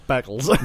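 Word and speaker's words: Beccles: Bickley. – Yeah. – It's Beckles.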